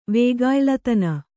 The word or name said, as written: ವೇಗಾಯ್ಲತನ